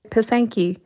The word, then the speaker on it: Pysanky: You also point out there is a long history of eggs as art objects, some of the most famous being the Pysanky. Is that how you say it? – Pysanky.